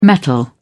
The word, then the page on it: metal: British English